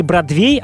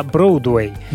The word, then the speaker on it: Бродвей: Например, не Бродвей, а Броудвей. или неправильное произношение русских слов, особенно схожих по звучанию с иностранными.